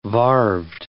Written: varved

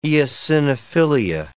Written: eosinophilia

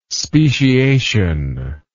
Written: speciation

